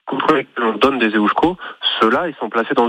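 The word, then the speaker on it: Eusko: Non, mais vous faites bien déjà, vous dites EOSCO, donc on va déjà le prononcer correctement, ça c'est la moindre...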